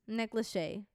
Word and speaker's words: Lachey: Kind of sounds like necklace. Nick Lachey.